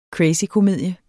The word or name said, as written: CRAZYKOMEDIE